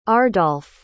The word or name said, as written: ardolf